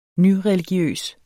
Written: nyreligiøs

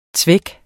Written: zweck

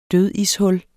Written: dødishul

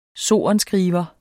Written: sorenskriver

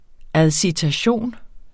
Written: adcitation

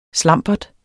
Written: slambert

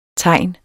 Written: tegn

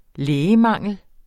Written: lægemangel

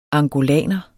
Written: angolaner

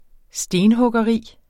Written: stenhuggeri

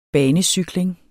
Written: banecykling

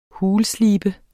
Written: HULSLEBET

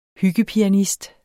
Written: hyggepianist

